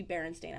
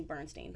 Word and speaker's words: Berenstain: Like so I pro- I think I'm pronouncing it's supposed to be Berenstain. I'm pronouncing Bernstein.